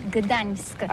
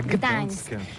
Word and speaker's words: Gdańsk: Ça s'écrit G-D-A-N-S-K. On dit Gdansk ou Gdansk, on prononce le G ou pas? – Oui, c'est pas très facile pour des étrangers, mais remarque bien que la langue polonaise, c'est la langue où on prononce chaque lettre qu'on écrit. C'est pourquoi on dit Gdańsk.